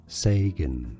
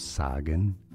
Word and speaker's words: Sagan: How do you pronounce the name of this American astronomer? Sagan, not Sagan. Say.